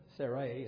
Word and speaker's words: Sarai: Sarai is the way it's still pronounced here.